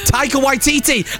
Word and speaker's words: Taika Waititi: That's how you say it, Taiki Waititi. Taika Waititi! I said it wrong then!